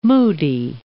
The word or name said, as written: moody